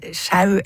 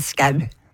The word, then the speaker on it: Skau: Og uansett, ja altså, jeg tror virkelig ikke det noen grunn til å uttale det ølet som hverken sjau eller show eller noe som helst annet enn skau.